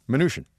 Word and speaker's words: Mnuchin: Yeah, this is just since we also had Steve Mnuchin back in our wheelhouse. – Yep. – Mnuchin? – Yep. – How do you say it? – Mnuchin. – Mnuchin, like Mnuchin. Minutia. – Yes, sort of. Absolutely, yes.